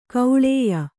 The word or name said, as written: ಗೂಳಯ